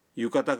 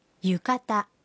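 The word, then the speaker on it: یوکاتا (浴衣): یوکاتا کا درست تلفظ ہے یوکاتا. یوکاتا.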